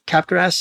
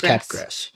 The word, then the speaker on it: Capgras: Capgras syndrome. – Okay. So this one is another party trick. – As opposed to crabgrass syndrome. This is Capgras.